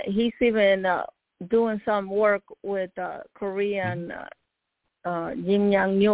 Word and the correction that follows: Kim Jong-un: what do you say his name? Kim Jong-un.